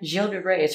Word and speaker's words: Gilles de Rais: Gilles de Rais is French. – Gilles de Rais. – Gilles de Rais. I thought it was like Giles or something.